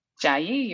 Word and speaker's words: ChiaYi: In Taiwan and ChiaYi, is that right? – ChiaYi, you're correct.